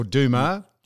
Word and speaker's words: Dumas: So Charles Dumas. – Beg your pardon? – Charles Dumas, or Dumas. – Yeah, D-U-M-A-S.